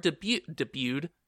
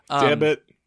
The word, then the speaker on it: debut: Debit. It's pronounced debut.